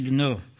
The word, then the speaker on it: Dunant: Ich habe ein Zeugnis von Henry, ich weiß nicht, wie er richtig ausgesprochen wird, Dunant, wahrscheinlich wird er französisch wie ausgesprochen. Dunant, ja, Henry Dunant. Jetzt habt ihr es gehört.